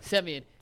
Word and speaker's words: Semien: It's Semien, correct? – Semien.